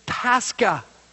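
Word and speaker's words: Pascha (πάσχα): The word for suffer is pronounced Pasco in the Greek, and the word for Passover is Pascha.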